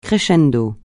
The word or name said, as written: crescendo